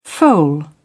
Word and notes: foal: British English

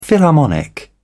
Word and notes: philharmonic: British English

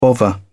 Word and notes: bovver: British English

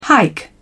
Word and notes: hike: British English

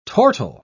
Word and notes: tortile: British English

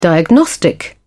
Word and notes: diagnostic: British English

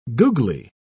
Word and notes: googly: British English

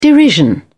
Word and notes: derision: British English

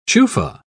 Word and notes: chufa: British English

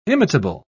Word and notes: imitable: British English

